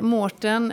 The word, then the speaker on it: Maurten: du, du hör ju, alltså det finns inte ens sportsliga Du, eh, Tobias, vi konstaterade att eh, Mårten eh, AU, fast vi säger Å, eh, är, så vi uttalar det.